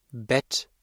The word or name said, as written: Bett